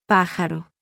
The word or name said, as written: p